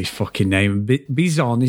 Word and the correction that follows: Besson: Is it B? What is it, Dave? I keep saying it wrong. – Besson. – Besson. Right, Besson, that's him as well.